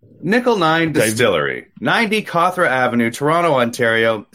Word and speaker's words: Nickel 9 Distillery: Is it Nickel 9 Distillery? Or because I've also heard Nickel 9 Distilleries. What's the proper way to say it? – Distillery.